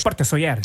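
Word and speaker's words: Port de Sóller: Porte de Soler. Ich glaube, es spricht man anders aus. – Sí, es ist Porte Soler.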